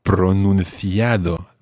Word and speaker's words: pronunciado: Pronunciado. That's right.